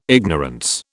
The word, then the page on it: ignorance: British English